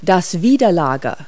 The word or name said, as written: abutment